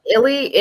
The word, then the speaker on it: Illy: Is that how you say it? – Illy, and it was so good.